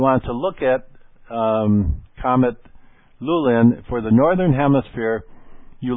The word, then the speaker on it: Lulin: So Comet Lulin, L-U-L-I-N is spelled, Comet Lulin, L-U-L-I-N, and its official designation is C-2007-N3.